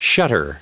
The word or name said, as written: shutter